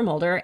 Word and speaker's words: Moulder: So I am on LinkedIn primarily, Heather Mulder, M-O-U-L-D-E-R.